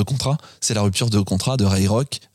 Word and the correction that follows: Kroc: Ray Kroc, j'arriverai mm. pas à le dire, Ray Kroc, euh, avec.